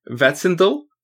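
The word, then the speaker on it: Vatnsdal: Calum Vatsindal.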